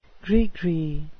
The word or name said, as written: gris-gris